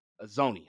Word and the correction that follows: Anzalone: However you say his name. – Anzalone. – I'm butchering his name.